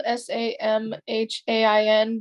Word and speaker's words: Samhain: So Samhain, it's it's spelled S A M H A I N, but it's pronounced Samhain.